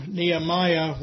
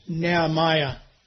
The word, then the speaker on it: Nehemiah: But I've got you to let you know that I was wrong. It's Nehemiah. It's not Nehemiah, Nehemiah. Okay, and that's the good and correct Scottish pronunciation, and don't you forget it.